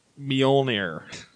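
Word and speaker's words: Mjölnir: Mjolnir. – Mjolnir. – I don't think the N is that. I think it's like Mjolnir. – Mjolnir. – You're not going fast enough. Faster. – Really fast. Mjolnir.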